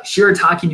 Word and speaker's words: shirataki: shirataki noodles. – That was good. – Yes, yeah. Uh, uh I said that right? Yeah, that was good.